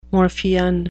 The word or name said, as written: morphean